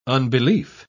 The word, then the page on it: unbelief: British English